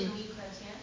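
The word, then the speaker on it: Minuit, Chrétiens: How would you pronounce that? Minuit Christian? – Minuit Christian.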